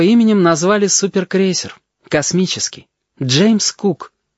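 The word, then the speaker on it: Cook: Это пишется «коок», а читается «кук» по-английски. Правда, там на конце буква «К» другая.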